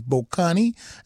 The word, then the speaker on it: Bokani: Again, his name is Bokani, and it's spelled B-O-K-A-N-I, Bokani Dyer, D-Y-E-R, Bokani Dyer at the piano, Radio Seshaba, the title of that particular recording.